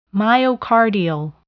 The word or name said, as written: myocardial